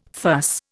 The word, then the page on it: fuss: Indian